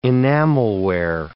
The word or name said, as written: enamelware